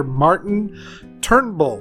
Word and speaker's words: Martin Turnbull: With us is author Martin Turnbull. Did I pronounce it correctly, sir? – Yes, it is.